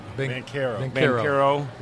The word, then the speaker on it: Banchero: Is it Banchero or is it Banchero? – Banchero. We've been trying to get T.O. to pronounce that right all time.